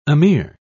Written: emir

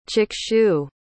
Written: chakshu

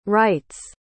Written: rite's

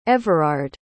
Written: everard

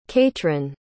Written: caitrin

